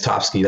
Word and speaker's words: Txpski: Um, yeah, just and it's Topsky. That's how you pronounce the name.